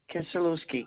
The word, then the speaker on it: Kisielewski: Bonnie Kisielewski, K-I-S-I-E-L-E-W.